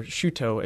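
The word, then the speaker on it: Chouteau: August Choteau, or Choteau as his French name would have been pronounced, but we don't pronounce today, he landed at the site that would be St. Louis.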